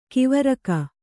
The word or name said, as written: ಕವರಗಿ